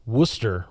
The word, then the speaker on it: Worcester: And so, but you've ended up in Worcester, right? Did I pronounce that right? – Good enough. At least you didn't say the R.